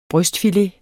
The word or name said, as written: brystfilet